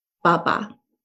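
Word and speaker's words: ba (八): And so, like in Chinese, you will say father, baba, right? And the number 8 is pronounced ba.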